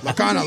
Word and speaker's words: Lorcana: I'll be looking for him to sign my Lorcana card. I said it right, right? – Yep. Yes. Lorcana. – Lorcana, Lorcana, Lorcana.